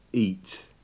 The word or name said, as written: eat